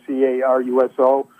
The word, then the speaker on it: Caruso: It's spelled Caruso.